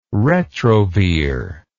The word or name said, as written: retrovir